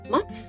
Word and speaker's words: ma2: 松さんと読むんですが、表記はアルファベットの小文字で MA、そして数字の 2、 MA2 と書いて松さんと読むようです。